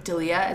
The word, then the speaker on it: D'Elia: D'Elia, is that how you say it? – Yeah.